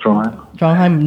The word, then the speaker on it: Trondheim: I know you're from Trondheim. I don't know if I pronounced that right. How do you pronounce it? – Yeah, that's correct. Trondheim, Norway, right? – Yeah. Trondheim.